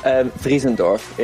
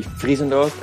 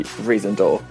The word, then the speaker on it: Vriesendorp: Friesendorf. In a fully Dutch accent would be Friesendorf. In a like more English accent would be Riesendorf. Or in an American accent maybe Riesendorf.